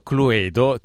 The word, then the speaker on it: Cluedo: Allora, innanzitutto Cluedo, ti do una brutta notizia, ma la pronuncia è Cluedo, perché Clue.